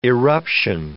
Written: eruption

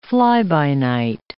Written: fly-by-night

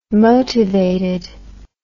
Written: motivated

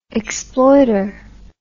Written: exploiter

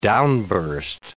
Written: downburst

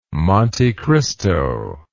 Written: Monte Cristo

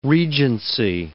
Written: regency

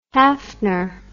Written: Haffner

